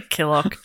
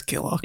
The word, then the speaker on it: Kilcock: It's probably Kilcock, but That's funny. – Come on. – Come on. – There's a comedy pronunciation and then there's Killock.